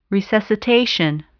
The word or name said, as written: resuscitation